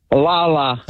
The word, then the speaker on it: Lala: Are they pronouncing this one Layla or Lala? – Lala. – Okay. Yeah, I kind of figured as much. There's no I in the middle or Y in the middle of it.